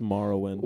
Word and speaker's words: Morrowind: It's pronounced Morrowind.